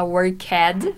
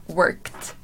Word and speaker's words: worked: Cuidado com a pronúncia, a gente não fala worked, fala worked. Ok?